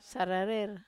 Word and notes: sararer: wamesa

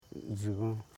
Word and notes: dgigon: dgi.gon

